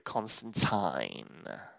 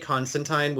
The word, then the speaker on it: Constantine: You know, the British would call it Constantine. – Yeah. Well, you know, the funny thing is, my one boyfriend pointed out that apparently Constantine was the originally intended pronunciation, and it just kind of got turned into Constantine. Personally, I prefer Constantine, and you will hear me say both, but, you know, you do you, boo-boo.